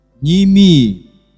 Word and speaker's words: Неемии: Вы слушаете серию проповедей Церкви Новый Завет по книге Неемии. Добрый вечер всем вам, дорогие друзья. Сегодня мы начинаем с вами изучать книгу. Какую мы книгу сегодня начинаем изучать? А вы знаете, как правильно говорить? Неемии.